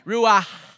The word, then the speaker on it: ruach: It's kind of like you have like popcorn in the back of your throat. Ruach, right? That's really how you say it, right, Will? Am I saying it right? Ruach. And then this is the definition.